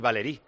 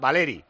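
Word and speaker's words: Valery: Que está diciendo que Valeri, se pronuncia Valeri y Valerí. desde la, de los pocos nombres que yo he oído con las tres, los tres acentos. Pero este es Valeri.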